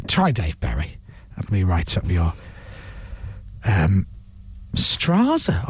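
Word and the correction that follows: Straße: I almost said there. Might be a word for street or avenue. – German. – But am I saying it right? – Strasse. – Right.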